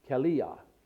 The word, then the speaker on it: Calah: And in the Hebrew, and Calah is pronounced Kaliah.